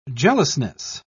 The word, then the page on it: jealousness: British English